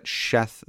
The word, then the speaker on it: Sheth: I'm always at a loss as to why they translate that Sheth there, or they transliterate it Sheth there. I mean, that is the pronunciation, but elsewhere this name is written in English Seth, one of the sons of Noah.